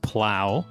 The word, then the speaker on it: plough: I didn't correct you because I wasn't sure, but P-L-O-U-G-H is pronounced plow.